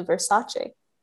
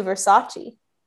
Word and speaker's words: Versace: And I'm really wondering how it will compare to The People versus OJ and to Versace. And I mispronounced that. And to Versace.